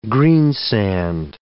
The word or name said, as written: greensand